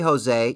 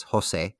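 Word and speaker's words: José: I found it was just easier to just, you know, hey, Jose, you know, that's not how you say it in Spanish. In Spanish, it's Jose. But they're like, Jose, right?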